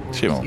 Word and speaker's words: Schimmel: Wasn't sure how you pronounce the. Is it Schimmel? – Schimmel.